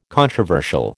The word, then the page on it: controversial: American English